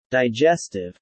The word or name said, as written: digestive